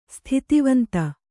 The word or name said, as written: ಸ್ಥಿತಿವಂತೆ